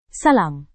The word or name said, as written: salam